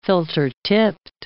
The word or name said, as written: filter-tipped